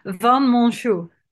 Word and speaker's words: van Monsjou: Van Monsjou.